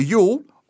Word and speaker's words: Yule: Well, there was the Feast of Yule, J-U-U-L. It looks like Jewel, but it's pronounced Yule, hence Yule log.